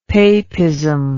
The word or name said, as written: papism